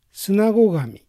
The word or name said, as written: Sunagogami